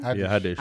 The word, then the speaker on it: Haddish: Haddish.